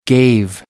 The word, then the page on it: gave: American English